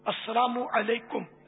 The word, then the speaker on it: السلام علیکم: اچھا اب جو کرتے ہیں بدقسمتی سے ان میں بھی بہت تھوڑے ہیں تلفظ جن کا صحیح ہو، بہت تھوڑے. السلام علیکم، السلام علیکم سر، کم ہاں اس طرح بس طرح طرح کی آوازیں نکالتے ہیں. حالانکہ عربی میں یہ سلام ہے السلام علیکم، یہاں تک کہ اگر میم پر پیش نہ پڑے نا السلام علیکم کا، تب بھی جواب واجب نہیں ہوگا.